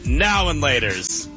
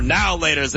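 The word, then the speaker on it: Now and Later: I say now and laters, you say now laters, like it's all one word.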